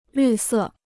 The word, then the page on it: 绿色: lǜ sè